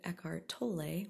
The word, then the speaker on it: Eckhart Tolle: I can exist in silence and in those spaces of silence through both listening to Eckhart Tolle, which is how you properly say his name. I definitely have pronounced it wrong in the past if you've heard me on any other videos.